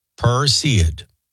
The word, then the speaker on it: Perseid: The heavens will put on a show this week as the Perseid. Am I saying that right? Perseid? I believe so. Perseid.